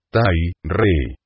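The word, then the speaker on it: Tai re: Debe cantarse este mantra acentuando fuertemente la vocal A. Tai. Las tres sílabas restantes se vocalizan dando a la E un sonido acampanado, melodioso, prolongado. La R no se hace vibrar, sino solo se pronuncia de una manera simple: re.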